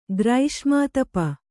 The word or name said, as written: ಗ್ರೈಷ್ಮಾತಪ